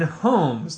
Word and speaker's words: Holmes: By the way, it's pronounced Sherlock Holmes, as in Holmes, the place people live.